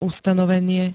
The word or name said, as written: ustanovenie